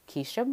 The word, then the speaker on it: Keshia: You can find me on all social platforms, which is Keisha Martin, K-E-S-H-I-A, Martin.